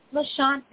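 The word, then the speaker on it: Lisjan: Ohlone, how do you pronounce the rest of that? – Lishan, that's right.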